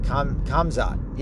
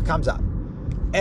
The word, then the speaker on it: Khamzat: I think I said Khamzat. Yeah, Khamzat. Everybody's betting on this guy.